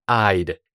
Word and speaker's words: I'd: Es muy importante pronunciar esa D en I'd, ya que es la contracción de I would.